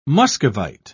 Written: muscovite

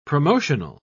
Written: promotional

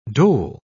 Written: dole

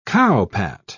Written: cowpat

